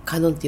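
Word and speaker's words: 風音: はい、これ最初お店見た時に、入り口からですね、それこそキッチンまでスッと風が流れるようなお店だったので、うん、音楽が風のように流れるお店だったらいいなって思って、カノンっていう名前にしたんですけれども、風の音と書いてカノンって読みます。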